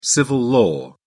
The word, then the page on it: civil-law: British English